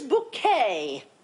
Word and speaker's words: Bucket: My name is Bouquet. B-U-C-K-E-T. No, it's not bucket, it's bouquet.